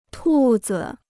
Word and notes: 兔子: tù zi

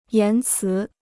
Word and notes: 言辞: yán cí

